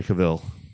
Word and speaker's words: Cavill: So, what do you think about Henry Cavill? – Cavill? – Cavill. That's how you pronounce his name.